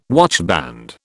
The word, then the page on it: watchband: British English